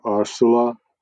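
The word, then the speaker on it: Ursula: poznámka, ona, že jo, říkáme ji Uršula, ale měli bychom to vyslovovat nějak jako Arsula, ale asi ji budeme říkat Uršula, jako všichni v Čechách.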